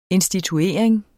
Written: instituering

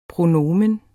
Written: pronomen

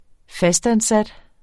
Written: fastansat

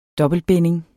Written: dobbeltbinding

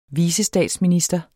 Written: vicestatsminister

